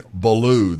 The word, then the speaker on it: Baloo: Yeah, if I say that right, it's actually Baloo the Bear.